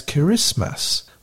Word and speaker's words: Kiritimati: Likewise, the Gilbertese word for Christmas is spelled with a T-I, both in the middle and at the end, K-I-R-I-T-I-M-A-T-I, so it's pronounced as Kirismas, which is very close to the standard English pronunciation, Christmas.